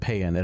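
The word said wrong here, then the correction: Paean: It actually is pronounced PN.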